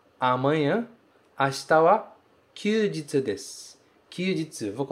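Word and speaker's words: kyujitsu (休日): Se pronuncia kyujitsu. Kyujitsu, ok? Esse kanji de dia que vem no final, que significa sol também, aqui ele é lido jitsu.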